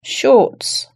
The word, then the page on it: shorts: British English